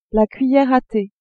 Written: cuillère à thé

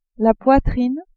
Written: poitrine

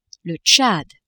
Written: Tchad

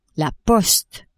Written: poste

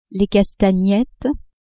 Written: castagnettes

